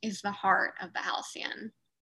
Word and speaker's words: Halcyon: So once you reach your destination, which is the Halcyon, I looked up the pronunciation because I was also calling it the Halicon,